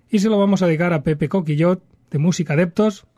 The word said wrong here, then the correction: Coquillat: El apellido lo he dicho bien, ¿no? – No. Es la A en vez de la O. – Ah, Coquillat. Muy bien, Coquillat.